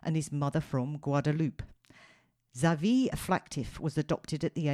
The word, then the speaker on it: Xavier: The French pronunciation is Xavier, not an H as I want to do, or Xavier as some. So we're thinking X-Men here, yeah.